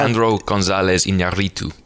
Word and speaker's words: Alejandro González Iñárritu: Alejandro Gonzalez Iñárritu.